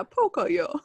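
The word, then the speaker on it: Pocoyo: It's P-O-C-O-Y-O. – Pokoyo. – Yeah, Pokoyo. That's how they say it,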